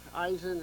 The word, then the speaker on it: Eisen: It's Eisen, and it's short for like Eisenhower, Eisenstein. But